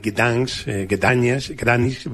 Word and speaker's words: Gdańsk: De esta última, de Gdańsk, pronunciado en polaco, yo elegiría lo que ellos llaman la calle larga.